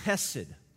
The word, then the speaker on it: chesed: But this word is chesed. And it's spelled C-H-E-S-E-D. Chesed.